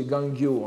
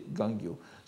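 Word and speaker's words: Gangyo (元暁): Gangyo, prononcé Gangyo en japonais, et dans les traditions des manuscrits du Senjusho, on donne souvent la, lecture Gangyo. Mais euh, la lecture courante est Gangyo.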